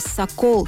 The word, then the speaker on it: сокол: Это бревно называлось сокол с ударением на второй слог.